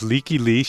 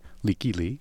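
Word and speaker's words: Lykke Li: The artist is Leaky Lee. She spells her name L-Y-K-K-E. Her last name is spelled L-I, Leaky Lee.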